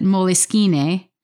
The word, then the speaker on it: Moleskine: Wait, is it moleskin, moleskine, moleskine, or moleskina? I'm so confused, and I think I've been saying it wrong for years. Well, here's something that'll make you feel better. I actually looked it up, and the CEO of the company, who's Italian, so he would likely pronounce it Moleskine, mm-hmm.